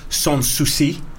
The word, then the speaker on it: Sans Souci: We also have Sans Souci. – Nice. – I'm hoping I pronounced that right. – I think you did.